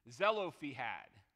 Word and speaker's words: Zelophehad: I want to make sure I get this right, I spelled it out to get the pronunciation, Zelophehad, the daughters of Zelophehad.